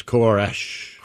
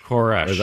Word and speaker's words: Koresh: Asked him how to, the negotiator asked him, how do I say your name? – Okay. – And then he says it. – Okay. – It's Koresh. – Koresh. – That's what he says.